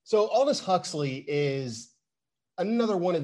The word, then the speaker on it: Aldous Huxley: So, John, you chose a quote from, I believe you pronounce it's Aldous Huxley is how you pronounce the name.